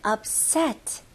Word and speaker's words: upset: 네, 여기서는 p 다음에 바로 s가 나오니까 p 발음이 좀 약화가 돼요. 그래서 너무 p 발음 강하게 하는 것보다는 upset. 그래서 바로 입술이 붙고 나서 바로 s로 넘어가시면 돼요.